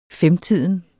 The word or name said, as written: FEMTIDEN